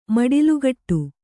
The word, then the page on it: ಮಡಿಲುಗಟ್ಟು: maḍilugaṭṭu